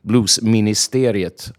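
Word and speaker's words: Bluesministeriet: yeah that's great um, you're also uh, the host of a um, blues radio show blues ministeriet am i saying it right yeah blues ministeri which of course i say the swedish way but it, the, the the the fact is it's on Finnish National Radio, Yle One, yeah.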